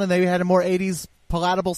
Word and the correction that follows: palatable: It was more palatable, but that pronunciation was not palatable. – There you go. I had the wrong emphasis on the wrong syllable.